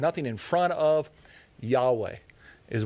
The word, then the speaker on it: Yahweh: He's calling himself Yahweh. The actual letters is YHWH, but the best way to pronounce it is Yahweh.